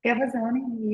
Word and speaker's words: Gavazzoni: Gavazzoni, you said just right.